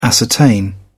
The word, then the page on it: ascertain: British English